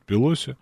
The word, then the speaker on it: Пелоси: Кстати, тут тоже вопрос у меня был, Пелоси все-таки или Пелоси. Мне кажется, что Пелоси, но правильно говорят Пелоси.